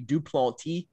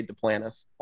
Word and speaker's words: Duplantis: I guess the real French pronunciation of my last name would be Duplantis. But here in South Louisiana, we say Duplantis.